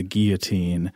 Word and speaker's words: guillotine: It's got to be the, and Robert, before I say it, do you say it like a French guy's name or like what a fish breathes with? – I go with guillotine because it sounds a little more like an open-faced sandwich that way.